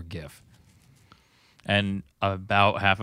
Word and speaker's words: GIF: Okay, so honest to goodness, do you guys pronounce it GIF or JIF? – I pronounce it like it's supposed to be pronounced. – GIF. – Which is GIF. Because it has a G, not a J.